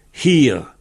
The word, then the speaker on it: hir: Non, en français c'est M-E-N-H-I-R. Alors, en breton, ur men hir s'écrit M-A-E-N et H-I-R. Men, M-A-E-N. Hir. Alors, ça veut dire une pierre longue. Alors, hir, il y a un H. Donc, si les auditeurs peuvent essayer de s'entraîner à prononcer le H un peu, hir.